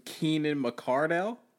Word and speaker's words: Keenan McCardell: McKeon Cardell. – Who? – McKeon Cardell. The Keenan McCardell? – Oh, see, I even said his name wrong.